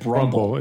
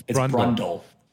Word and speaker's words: Brundle: You said Brundle. It's Brundle.